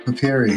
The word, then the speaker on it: papyri: Is that how I pronounce it? Papyri? Papyri? – Papyri. Papyri. – Papyri. Yes, Herculean papyri.